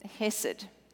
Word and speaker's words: hesed: The Hebrew word for mercy is hesed, H-E-S-E-D. Not sure I'm pronouncing it correctly, but hesed.